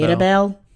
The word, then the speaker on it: Mirabel: So But doesn't she have to be like that to get to get um uh Maribel or however you say her name? – Maribel. Mirabel. – Mirabel. – Um, Mirabel.